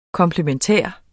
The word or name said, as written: komplementær